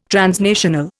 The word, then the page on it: transnational: Indian